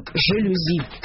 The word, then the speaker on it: жалюзи: Жалюзи. У словарей собственных вопросов никаких не возникает. Полное единодушие. Естественно, слово не склоняется. Никаких жалюзи. Жалюзи всегда жалюзи. Надо всего лишь разобраться, откуда взялось такое ударение, и тогда удивление исчезнет.